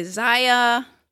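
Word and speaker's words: Xia: I was wondering how you were going to pronounce it. Because I hear people say Zaya. – I think it's Shea.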